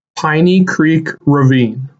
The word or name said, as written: Piney Creek Ravine